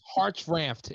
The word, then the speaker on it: Hartranft: How do you say that, Ben? – It's Hartranft. It's okay.